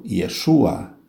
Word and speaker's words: Yeshua: Jesus, pronunciado Yeshua em hebraico,